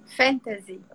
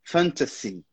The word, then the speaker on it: fantasy: Fantasy. – Quase lá. Na verdade, aquele S ali no final tem um som de S mesmo. Fantasy.